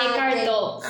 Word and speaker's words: Eckhart Tolle: Ah, eh, no sé si se, pronuncia bien, de Hector Tolle. – Ah, ah Eckhart Tolle, ah, sí, claro.